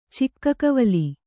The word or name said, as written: ಚಿಕ್ಕಕವಲಿ